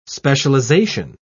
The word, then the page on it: specialisation: British English